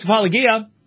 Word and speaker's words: apologia: At my first apologia is how it would be pronounced in Greek. But my first apologia, nobody showed up.